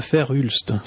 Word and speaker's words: Verhulst: En réalité, si vous allez regarder, euh, c'est très facile à trouver, c'est sur Wikipédia, vous tapez modèle de Fer-Hulst, ça s'écrit V-E-R-H-U-L-S-T. Alors Verhulst,